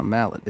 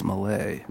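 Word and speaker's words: Mallett: It's like my last name. It's not Mallet. It's Malay.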